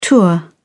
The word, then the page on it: tour of duty: British English